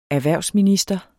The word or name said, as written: Erhvervsminister